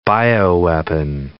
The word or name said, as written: bioweapon